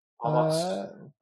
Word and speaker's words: Omos: Omos.